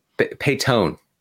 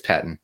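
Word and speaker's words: Paton: Does he pronounce it Patton, or does he say something weird like Peyton? – Peyton. No, I'm kidding. It's Patton.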